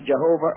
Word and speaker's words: Jehovah: and there's much discussion over the pronunciation, Jehovah or Yahweh, and the Jews were so afraid to pronounce it.